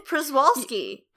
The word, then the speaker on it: Przewalski: Yeah, it's pronounced Przewalski. – Holy my God. Well, I've already learned something, because I always have tried to say the word, like, because it's PRZ. I've always been like, Przewalski. – Przewalski. Yeah, no, that's it. It's pronounced Przewalski. It's a lot of continents in a row.